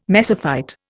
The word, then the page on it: mesophyte: Indian